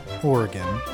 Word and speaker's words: Oregon: Oregon is, of course, pronounced Oregon. And we've covered this.